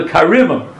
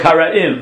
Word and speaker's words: Karaim: They were called the karimah. We call it Karaim, but in Yiddish, I guess, they pronounce it the Karima.